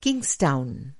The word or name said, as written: किंग्सटाउन